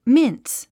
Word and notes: mince: míns; ミンス

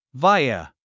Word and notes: via: váiə; バァイア

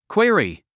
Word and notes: query: kwíəri; クイアリィ

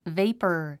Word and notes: vapor: véipə r; ベイパァ

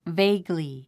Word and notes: vaguely: véiɡl; ベイグリィ

